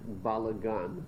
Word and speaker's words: balagan: So there was a big balagan. That's how you say it in Hebrew.